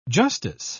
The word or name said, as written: justice